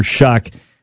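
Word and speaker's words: Shough: Don't look at the spelling of his name to pronounce it. Just say Shuck. That's what it is. Don't think about it. That's how you pronounce it. It's not shoe or show or anything what it would look like.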